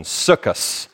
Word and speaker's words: Sukkot: as you hear in Ashkenaz pronunciation, Sukkot.